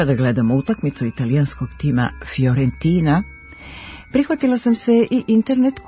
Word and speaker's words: Fiorentina: Ovo pravilo važi i za predvokalsko fi koje se čita i piše kao fj, Fiorentina, a ne Fiorentina.